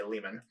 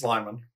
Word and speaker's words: Liman: It is Doug Liman, and I still always want to say Lehman. – It's Liman.